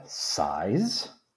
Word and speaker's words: Size?: How do you spell that? – S-I-Z-E question mark. – Size? – Oh, okay. – Size? – I thought it'd be S-I-G-H-S. Oh, like, oh. Ugh, clothes. – Like the bridge of size.